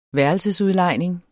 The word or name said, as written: værelsesudlejning